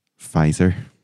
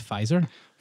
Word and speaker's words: Pfizer: Pfizer. – Oh, wow. – With a silent P. – Yeah, because written down here, it looks ridiculous. – It's a strange word. – P-F-I-Z-E-R. And you're saying it's Pfizer? – Pfizer.